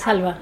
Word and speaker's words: halva: Do you pronounce it halva? – Yes. Yes, halva.